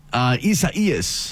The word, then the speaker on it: Isaias: Isaias.